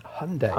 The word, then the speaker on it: Hyundai: Hyundai. – No, it's actually pronounced Hyundai.